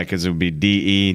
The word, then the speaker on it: De: Yeah, we should It's D Williams. That's his thing? It's actually it's spelled D E, so it's de, but everybody says D, so I guess we just say D.